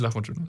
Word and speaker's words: Lahmacun: Ich glaube, Lahmacun ist richtig.